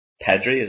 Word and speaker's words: Pedri: That was kind of, this is kind of the first game where Pedri, is that how you pronounce his name, correctly? – Yeah.